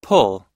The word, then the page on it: pull: u